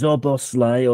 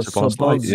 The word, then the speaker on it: Szoboszlai: I've got to pick you up on the pronunciation, though. I've heard this so many times. It's Szoboszlai or Szoboszlai. – Yeah, I don't know. – No, I know. It's very easy because SZ in Hungarian is just S.